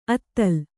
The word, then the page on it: ಅತಲ: Kannada